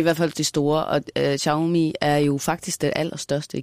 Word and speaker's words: Xiaomi: Men Heine, hvis Apple ligesom kommer ind og bliver sådan et prestigeprodukt, hvad så med de kinesiske mærker som Xiaomi? Sådan tror jeg, det udtales. – Xiaomi.